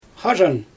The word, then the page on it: xaaʒan: Xyzyl